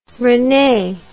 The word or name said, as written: rene